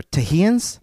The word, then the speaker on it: Texians: It is pronounced T-E-X-I-A-N-S. – Texians? – Texians? – Texians? – I don't know. It's weird to me. So, I'm just going to continue saying Texans. – Texians. I mean, that... – But that's how it was spelled in everything. – I wonder, would they pronounce that X like an X, maybe? Or Tahians? – Tahians, that's more what I was thinking, because it's still kind of transitioning from Mexico to...